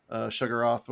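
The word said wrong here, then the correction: Shuma-Gorath: Shumagoroth, there it is.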